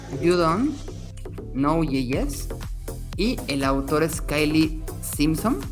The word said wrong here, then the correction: Kyle Simpson: Kyle Simpson, espero, espero, pronunciarlo bien, Kyle, Kyle Simpson.